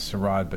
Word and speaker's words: sarod: There's a few people that call it the Sarod, but no, it's pronounced Sarod.